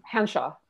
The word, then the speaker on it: Hanshaw: How do you say her name? – Hanshaw. Annette Hanshaw.